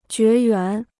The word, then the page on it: 绝缘: jué yuán